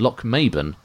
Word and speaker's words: Lochmaben: Socky, as in Socky Burn, is pronounced Socky, and Lochmaben is pronounced Lochmaben.